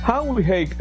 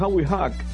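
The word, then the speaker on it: Howie Haak: en los 80 Howie Hake. Howie Hake, muchos decían Howie Hack, bueno, así que se escribe.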